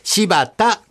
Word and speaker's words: 新発田: 今日は柴田のお菓子です。柴田というのは新潟県の地名。漢字で書きますと、新しいという字に出発の発、田んぼの田と書きまして、柴田と読みます。